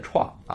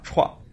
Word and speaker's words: TRON: 从这个角度来说，我们创业啊，也要选择规则没有制定好的领域。当然，比如说我们刚才举的例子，就是我们的区块链的新项目 TRON 这个创这个项目啊，确实就是如此啊。大家读音也要注意，要读创业的创啊，创。